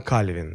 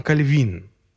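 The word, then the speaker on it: Calvin: А, или во французском языке все слова имеют ударение на последний слог. Вот. И когда допустим, сегодня мы говорим а, ну, имя известного реформатора, да, Жан Кальвин, да. Вот французам это будет резать слух, потому что а, в их произношении это будет Жан Кальвин.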